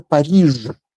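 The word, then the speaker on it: Paris: И вообще-то, конечно, нет никакого города Париж. Не существует его, потому что он Пари.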